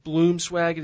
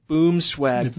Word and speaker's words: Boomswag: It is not Bloomswag, it is Boomswag Blinds.